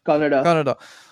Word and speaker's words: Kannada: Apparently correct my pronunciation here. Kannada? Is it Kannada? – Kannada.